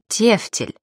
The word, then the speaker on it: тефтель: Правильно произносить «тефтель», «апостроф», «иконопись».